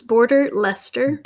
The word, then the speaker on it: Border Leicester: Yes, it's hard to pronounce. It's Border Leicester. – Border Leicester.